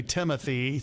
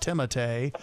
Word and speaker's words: Timothée: He kind of goes back and forth on how he pronounces his first name. A lot of people say Timothy. Sometimes it's just Timotei.